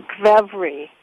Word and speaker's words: kvevri: And in Georgia, they have amphora-shaped vessels that they call kvevri. That's like K-V-E-V-R-I, kvevri.